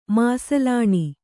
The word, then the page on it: ಮಸ್ಲಿನ್: maslin